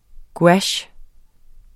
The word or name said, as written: GOUACHERNES